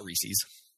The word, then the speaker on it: Reese's: Did you say Reese's? – Yes, Reese's eggs. – Okay. Uh-huh. It's not Reese's. It has to rhyme with pieces. Pieces. The Reese's pieces. Not Reese's pieces.